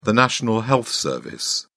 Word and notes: national health service: British English

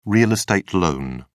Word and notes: real estate loan: British English

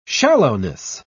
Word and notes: shallowness: British English

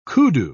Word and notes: koodoo: British English

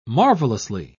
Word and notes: marvelously: British English

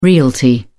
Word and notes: realty: British English

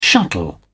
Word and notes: shuttle: British English

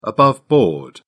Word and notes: above board: American English

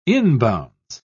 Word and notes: inbounds: British English

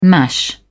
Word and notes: mash: British English

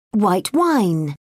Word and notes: white wine: British English

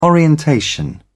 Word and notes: orientation: British English

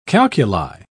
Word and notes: calculi: British English

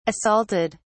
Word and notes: assaulted: British English

